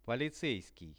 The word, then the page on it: policeiskij: Russian